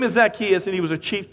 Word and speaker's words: Zacchaeus: Once you go to Bible college, you realize it's Zacchaeus, but you can't get over saying Zacchaeus because it rhymes in the little song.